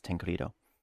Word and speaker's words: Tancredo: Tancredo. – Won't Credo have an extra in it though? – No. It's like I looked up the pronunciation of it because I've never seen that before. And it's Tancredo.